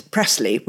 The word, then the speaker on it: Presley: Um, the very limited amount that I knew about Priscilla Presley, which is Pres- Presley, we should say. The, the family name has always been pronounced Presley,